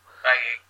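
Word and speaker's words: Bailly: I've gone for Pickford, Chambers, Smith, and Bailey. Even though it's not pronounced Bailey, is it? But you know what I'm talking about. – Bailey.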